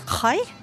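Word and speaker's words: chai (חי): Det er et eh, hebraisk tegn eh, som eh, uttales chai.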